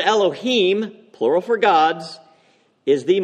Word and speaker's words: Elohim: In Hebrew, the word here that Asaph uses in Hebrew is pronounced Elohim.